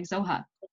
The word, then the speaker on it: Zoha: But someone like me could have a really short and very relatively easy to pronounce name like Zoha. – Yeah. – It's 4 letters, 2 syllables. I don't know how people butcher it, but they do. – Do they? – In school, Joha, Zaha, Zola, Zara, Zoe.